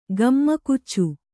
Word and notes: ಗಮ್ಮಕುಚ್ಚು: gammakuccu

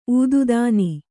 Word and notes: ಊದುದಾನಿ: ūdudāni

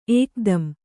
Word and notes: ಏಕ್‍ದಂ: ēkdam

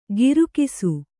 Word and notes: ಗಿರುಕಿಸು: girukisu

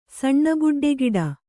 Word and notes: ಸಣ್ಣಬುಡ್ಡೆಗಿಡ: kannada

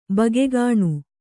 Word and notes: ಬಗೆಗಾಣು: bagegāṇu

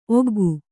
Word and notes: ಒಗ್ಗೈ: Kannada

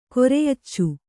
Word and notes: ಕೊರೆಯಚ್ಚು: koreyaccu

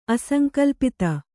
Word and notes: ಅಸಂಕ್ಲುಪ್ತ: asaŋklupta

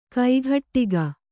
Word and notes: ಕೈಗಡಿಕ: kannada

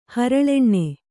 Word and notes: ಹರಳೆಣ್ಣೆ: Kannada

